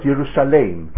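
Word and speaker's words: Yerushalayim (ירושלים): It's spelt Yerushalayim, and it's pronounced Yerushalayim.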